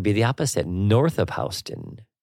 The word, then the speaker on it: Houston: That means south of Houston, which looks like Houston. – Yeah. – But it is pronounced Houston. – Exactly. – So it's south of Houston. If you're visiting New York City, you never say Houston. You say Houston, just to let you know.